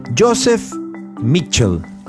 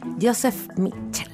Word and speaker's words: Joseph Mitchell: Así se dice. – ¿Cómo se dice? – Joseph Mitchell.